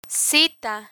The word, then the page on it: cita: spanish